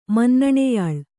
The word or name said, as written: ಮನ್ನಣೆಯಾಳ್